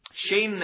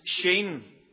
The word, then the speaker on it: Shin: Shin, it's spelt S-C-H-I-N, but it's pronounced Shin. We would say Shin, probably, looking at it, but it's pronounced Shin.